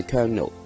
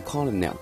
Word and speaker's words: Colonel: 在这里是要多谢一位听众三毛，他提醒我 Tom Parker 上教英文应该读做 Colonel Tom Parker，而我在上个礼拜介绍的时候是读错了 Colonel Tom Parker。在这里是要做一个更正，上教的英文读音是 Colonel 不是 Colonel。